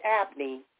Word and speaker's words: Abney: But like I said, if you Google Jeanette Abney, and my last name is spelled A-B-N-E-Y, you'll see who I am.